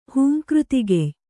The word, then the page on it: ಹೂಂಕೃತಿಗೆಯ್: Kannada